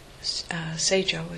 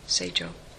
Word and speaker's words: Seijo: It was said in Tang Dynasty, China, there was this beautiful young woman named Seijo is the Sino-Japanese pronunciation of her name, Seijo.